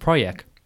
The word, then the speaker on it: Projekt: Apparently, it's pronounced Projekt.